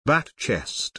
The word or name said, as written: BatChest